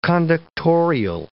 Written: conductorial

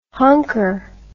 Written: honker

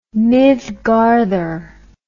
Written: mithgarthr

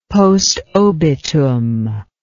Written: postobitum

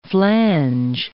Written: flange

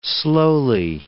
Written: slowly

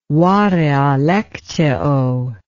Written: varia lectio

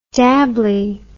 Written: dabbly

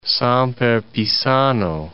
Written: Samper Pizano

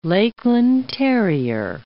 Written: Lakeland terrier

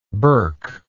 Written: burkite

